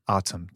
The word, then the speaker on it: ATUM: How do you pronounce ATUM? – Autumn.